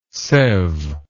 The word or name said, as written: seve